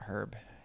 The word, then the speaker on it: herb: Yeah. Herbs. H-E-R-B. – Silent H.